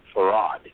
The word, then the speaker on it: Fard: Farad, spelled F-A-R-D, but pronounced Farad.